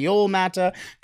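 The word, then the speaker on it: Joel Määttä: And then 222, the Edmonton Oilers pick Joel Matta, potentially Joel Matta, because he's Finnish. So that J might. The Edmonton Oilers might have just picked two guys with silent J's, and I'm all the way here for it.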